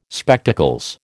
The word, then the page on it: Spectacles: American English